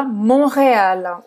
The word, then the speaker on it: Montréal: Montréal, em francês a gente fala Montréal. O T não se pronuncia.